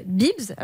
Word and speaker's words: Beebs: Je vous, donne un exemple, ça s'appelle Bibs. Alors, ça s'écrit B-E-E-B-S.